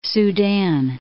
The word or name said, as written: Sudan